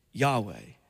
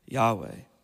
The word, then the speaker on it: Yahweh: And many have pronounced that name Yahweh. You heard that before? Yahweh.